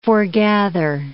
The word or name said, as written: forgather